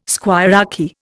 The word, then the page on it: squirearchy: Indian